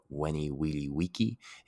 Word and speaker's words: veni, vidi, vici: If you have ever heard of the quote by Caesar, the very well-known quote, veni, vidi, vici, it's seen as veni, vidi, vici, or veni, vidi, vici. But remember, the V's are pronounced as W's in Latin.